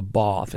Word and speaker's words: Bawth: Eloise book called Eloise Takes a Bath, and that's spelled Bawth.